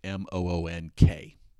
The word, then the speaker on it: Munch: I hate doing names sometimes because I tend to not get them right, but it's spelled Munch, but I did check it, so it's like it's more pronounced like Moonk.